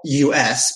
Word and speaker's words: Us: They're called the Us, which is spelled U-S, but it's pronounced Us like foosball table.